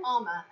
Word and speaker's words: Armour: Armour, as in knights in shining.